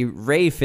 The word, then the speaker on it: Ralph: Rafe is such a talented actor, and everybody, it's pronounced Rafe, even though there's an L in there.